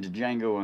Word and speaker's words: Django: Then Django on Django. – Fucking up. And then D is silent. I know that.